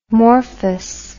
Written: morphous

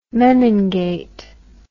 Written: menin gate

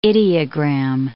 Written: ideogram

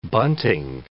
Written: bunting

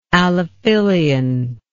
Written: allophylian

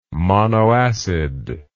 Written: monoacid

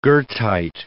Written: goethite

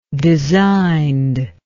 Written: designed

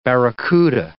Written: barracuda

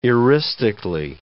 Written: eristically

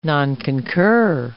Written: nonconcur

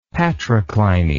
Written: patrocliny

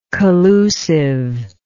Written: collusiveness

